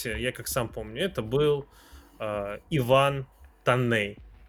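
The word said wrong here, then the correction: Toney: Если я правильно а, произношу. Тони, Тони, по-моему, да. да. Тони.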